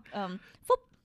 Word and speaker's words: Phuc: Phup. Phup, because it's spelled like it looks like the F word. – Oh, P-H-U-C. Phup. – Yeah. But like, you pronounce it like Phup.